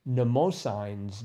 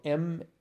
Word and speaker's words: Mnemosyne's: Another clue that was sort of in that a part of history, and I don't even, let's see, it's probably Mnemosyne's daughters. It's spelled Mnemosynes, and it's sort of like mnemonic, so Mnemosyne's daughters, the Muses.